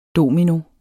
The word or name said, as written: domino